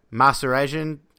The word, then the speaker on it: Mahserejian: But with me is my Roto Baller colleague, Kev Masarejan. I forgot to ask you how to pronounce it. Did I nail it? – You're two for two, basically, as close as it can get.